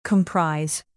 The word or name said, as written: comprise